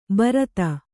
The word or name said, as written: ಭರತಿ